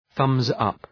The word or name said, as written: thumbs up